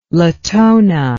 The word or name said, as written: latona